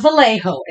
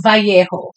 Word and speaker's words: Vallejo: It's not Vallejo. It's Vallejo.